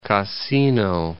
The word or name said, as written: Cassino